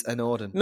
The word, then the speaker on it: anOrdain: AnOrdain? – Yeah, yeah. That is actually how you say it.